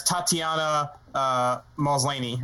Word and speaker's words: Tatiana Maslany: So the big one was they're casting Jamila Jamil as Tatiana Maslany, right? Hope I'm saying that right. – Yeah, that's how I would say it.